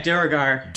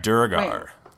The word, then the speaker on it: Duergar: And he was said to like be in cahoots with the drow and the man, I don't know how to say this, the Durgar. The Draugr? Like evil dwarves. – Draugr? – Yeah, Duergar. – Duergar.